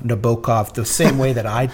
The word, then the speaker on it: Nabokov: Nabokov for some image I was working on or something.